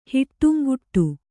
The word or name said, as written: ಹಿಟ್ಟುಂಗುಟ್ಟು